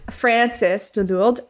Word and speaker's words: Frances de Lourdes: for the Soyuz rocket and the brands you'd buy them from because you recommended France de Lourdes years ago. Did I say that right? – Uh, Frances, but but well remembered. – Frances de Lourdes years ago on Fashion No Filter, and I still love it.